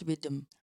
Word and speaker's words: Riddim: How do you pronounce Riddim? – Riddim.